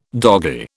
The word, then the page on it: doggy: British English